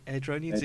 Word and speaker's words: Airdrieonians: Uh, at the moment, uh, Colin Cameron is the assistant manager at, I'm going to try and pronounce this, Airdrieonians, is that right? – Edronians? in uh, Stu, Stu's giving me the official nod.